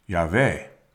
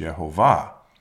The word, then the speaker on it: Yahweh: It is actually four letters, Y, H, V, and H, and is pronounced Yahweh. Phonetically, it is pronounced Yehovah.